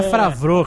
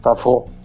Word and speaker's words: Favreau: Não é Favreau, cara. É francês. – Ele era um ator. – Favreau, como é que pronuncia? – Favreau.